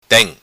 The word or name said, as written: Tem